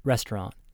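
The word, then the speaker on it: restaurant (ресторан): A word that looks like makpa is pronounced Moskva, and a word that looks like pektapa, pronounced restaurant.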